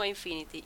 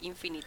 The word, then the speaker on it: Infinite: ¿Lo he dicho bien o Infinity? Infinite, ¿verdad? – Bueno, Bioshock, 3.